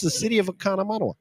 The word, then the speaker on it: Oconomowoc: You're throwing in like an extra syllable. – Oconomowoc. – Oconomowoc. Like mow the grass. Oconomowoc grass.